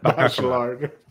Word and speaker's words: Bachelard: Did you say Bachelard or Bacacocac? – Bachelard. I mispronounce it all the time.